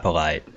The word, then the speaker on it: Polite: How do you say his name? – Ja'Kai Polite.